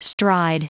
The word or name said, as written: stride